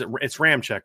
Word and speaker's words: Ramczyk: It's Ramczyk, right? Isn't that how you pretend to say his last name? Ryan Ramczyk. Yeah. Which doesn't look anything like Ramczyk.